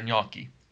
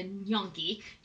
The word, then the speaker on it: gnocchi: Yes, they pronounce it like N, like ni. I don't know. I don't know what gnocchi. I don't know why they do that. But it's clearly an Italian word pronounced in Italian. – The GN in Italian is similar to the ñ in Spanish. It's like a ñ.